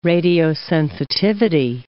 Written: radiosensitivity

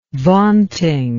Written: vaunting

